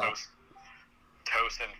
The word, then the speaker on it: Towson: It's pronounced Towson, but it or Towson, I'm not sure. – Like Shin goes, I think it's Towson. Shin's like something Eagles. Yeah, exactly. – But it's spelled T-O-W-S-O-N, like Towson.